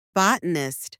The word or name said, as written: botanist